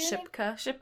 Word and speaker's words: Shipka: How do you say Shipka? – Kieran Shipka in Mad Men.